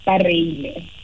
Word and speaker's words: Bareile: ba reile